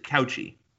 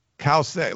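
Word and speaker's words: Cauce: Is it Kause or is it Couchy? – Kause. Like, I've looked up her pronunciation. They say Cow, C-O-W-S-A-Y. And it's C-A-U-C-E.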